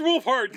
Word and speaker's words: Wolfhard: Mr. Wolfhard, can I have your autograph? – Hard. – Yes. – Like the word wolf and the word hard. – Yes. – And it's spelled just W-O-L.